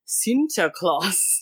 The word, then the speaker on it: Sinterklaas: What does, and this is how it's pronounced, Sinterklaas. – Sinterklaas. – Sinterklaas.